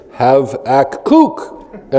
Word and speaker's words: Habakkuk: Similarly, I know there were guys who were leading the reading in Scripture, and they were in the book of Habakkuk. Do you guys know how that's actually pronounced? It's Havakuk, right? Havakuk, emphasis on the last.